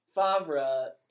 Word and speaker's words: Favre: His name is spelled F-A-V-R-E. – Oh. – So. – Favre, yeah, it's a French name. Like, he's from Mississippi, I think. Favre, he pronounces it Favre.